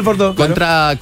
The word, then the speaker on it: Bordeaux: Y parte con ¿cómo se dice? Burdeos. – Bordeaux.